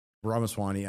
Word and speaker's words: Ramaswamy: Alex Jones, Gavin McGinnis, Vivek Ramsey, Ramaswamy. I always say Ramsey.